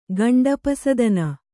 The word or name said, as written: ಗಂಡಪಸದನ